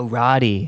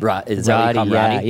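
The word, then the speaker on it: Rodaidh: Rodé McDonald? – Oh, Roddy. – Roddy. – Yeah, he's from Scotland, and so his name is spelled very intensely.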